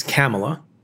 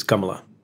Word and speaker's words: Kamala: The Indian way to pronounce her name is Kamala.